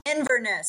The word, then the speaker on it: Inverness: What does Urbanist Dental Lab known for? What do you guys specialize in? – Inverness. Say that again. – What? Did I say it wrong? – Inverness.